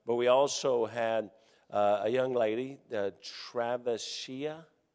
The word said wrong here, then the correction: Travesia: whoever... what? Travesia. Well, okay. As they said, I put the wrong emphasis on the wrong syllable.